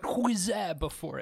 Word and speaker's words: Huizenga: How do you spell that? – Like Jenga, but Huizenga before it.